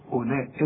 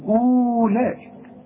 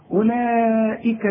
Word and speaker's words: أولئك: شوف أولئك، اوعى تقول له أولئك، لا، أولا كأن الواو غير موجود. أولئك الذين، الذين ما فيهاش مشاكل اهي لأنها مرققة.